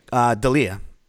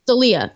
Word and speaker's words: D'Elia: So Chris D'Elia, how do you say his last name? – D'Elia. – D'Elia. Okay. Mm-hmm.